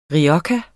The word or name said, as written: rioja